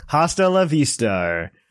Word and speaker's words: hasta la vista: Hasta la vista. – It's correct. – A silent H, but yes.